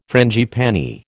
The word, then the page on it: frangipani: American English